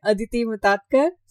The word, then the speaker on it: Mutatkar: So welcome, Aditi. Aditi Mutatkar. Is that pronunciation correct? – Oh, perfect. Absolutely right. Okay. – Great. So yeah, welcome, Aditi Mutatkar.